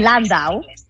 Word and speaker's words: Landau: Porque Christopher Landau es como con acento en la A.